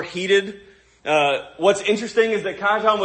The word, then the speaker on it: Cajetan: Cajetan, Cajetan, however you want to pronounce it, I looked this up. Cajetan is the official pronunciation.